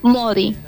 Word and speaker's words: Maudie: Modi, se escribe Maudie.